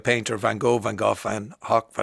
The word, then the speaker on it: Van Gogh: Stephen Fry and the QI Elves, that historical language experts have been insisting that Vincent van Gogh's name should not be pronounced as neither Van Gogh or Van Gogh, but actually Van Hock.